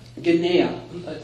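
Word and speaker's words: Genea (γενεά): And we talked about that word generation, genema, if I'm saying that right. – Genea. – For some reason, I think there's an M in there. Genea, which has to do with more than just generation.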